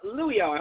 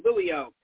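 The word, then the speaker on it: Liulo: One is PodCal, that's P-O-D-C-A-L, and the other is Luio. I hope I get the pronunciation right. It's L-I-U-L-O, Luio.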